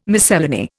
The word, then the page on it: miscellany: Indian